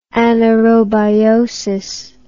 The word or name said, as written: anaerobiotically